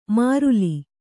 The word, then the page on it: ಮಾರುಲಿ: māruli